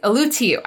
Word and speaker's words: Ulutiu: I feel like we pronounced that differently last time. – We did. I think it was Ulutiu. That I pronounced it last time, because every time I see the T-I-U, I want to pronounce it as shoe. But anyways, Ulutiu. I actually looked it up this time.